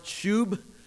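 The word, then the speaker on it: shuv: The Hebrew word here is pronounced shub.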